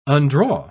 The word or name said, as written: undraw